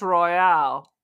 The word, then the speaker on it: Royale: But the way this is spelled suggests it's Royale, like Royale with cheese. So I don't know how it works. – Is there an E on the end? – Yes. – Then it's Royale.